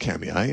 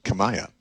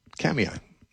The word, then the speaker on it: Kamiah: Now, if you're in central Idaho, I'm going to be speaking in a small town, Kamiai, in the Clearwater Valley, which if you look up on a map, it doesn't look like it should be pronounced Kamiai. It looks like it should be pronounced Kamiah. It's K-A-M-I-A-H. I guess it's a Nez Perce Indian word, and they pronounce it Kamiai.